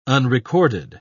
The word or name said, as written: unrecorded